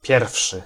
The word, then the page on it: pierwszy: [ˈpʲjɛrfʂɨ]